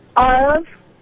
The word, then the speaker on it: Aarav: Arav. – Can you spell that? – A-A-R-A-V.